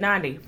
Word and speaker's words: Nandi: Because often when you look at my name, people assume that it's Nandi. I've never met a Nandi in my entire life, but that is people's first assumption of how you would say my name is Nandi. No harm, no foul. Do your best, right? But Nandi rhymes with Gandhi.